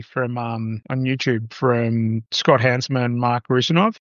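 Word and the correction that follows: Russinovich: Did I say that right? Yeah. – From Microsoft, right? Yeah. – Is it? – Yeah, it's Rusinovich. – Mark Rusinovich, yeah.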